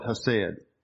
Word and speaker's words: hesed: I don't do the CH or the KH the way the Hebrew people do it. But it's basically hesed, H-E-S-E-D. They say it like that hesed. Sounds almost like a cat with a fur ball trying to get rid of it.